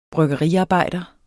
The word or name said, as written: bryggeriarbejder